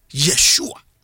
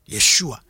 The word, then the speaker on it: Yeshua: Ye Yeshua. Yeshua.